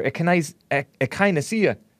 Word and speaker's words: echinacea: Drink some echinacea tea, or echinacea, some people pronounce it.